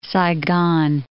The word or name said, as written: Saigon